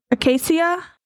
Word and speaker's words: Acacia: A-C-A-C-I-A. Acadia? Acacia? – Wait, spell it again. – A-C-A-C-I-A.